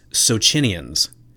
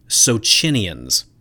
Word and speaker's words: Socinians: Now, of course, according to my Italian wife and all the Italian medievalists that I work with at Lucerne, The correct pronunciation of this Italian group is Socinians. Socinians.